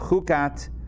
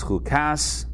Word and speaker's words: Chukat: All right, Wednesday, July 6th, 2022, and we are studying the Torah portion of Chukat, also known as Chukas, depending on your pronunciation of Hebrew.